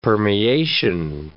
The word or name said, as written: permeation